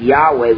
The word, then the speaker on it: Yahweh: By the way, Jehovah, Yahweh, the reason why two different pronunciations, some Hebrews pronounce the Y at like a J, some Hebrews pronounce the W like a V, and that's why we say Jehovah and Yahweh. Actually, to be more technically correct, Yahweh would probably be closer, and so our Jehovah's Witness friends need to understand that.